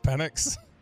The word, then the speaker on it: Penix: Is that because people don't know it's pronounced Penix? – That's probably why.